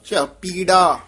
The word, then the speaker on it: चपीड़ा: पीड़ा नहीं बोलना ध्यान नाल पप्पा उच्चारण करना पप्पा नहीं बोलना चपीड़ा च पीड़ा यदि मेहनत नाल जानी पद शुद्ध होएगा चपीड़ा।